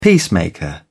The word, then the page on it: peacemaker: British English